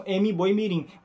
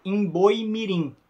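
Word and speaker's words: M'Boi Mirim: Essa estrada que, erroneamente, as pessoas pronunciam M-Boi-Mirim. Mas, na verdade, é Emboi-Mirim.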